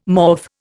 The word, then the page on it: morph: Indian